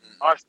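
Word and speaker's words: Oddisee: Odyssey. – But it's pronounced, like, the way it's spelled out is Odd I C, like S-E-E.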